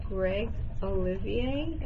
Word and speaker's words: Greg Olivier: And with that, I would like to welcome Greg Olivier. Am I saying that right? – You said that perfect.